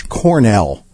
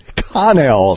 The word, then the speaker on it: Connell: Said they like Crash Cornell. It's Connell, C-O-N-N-E-L.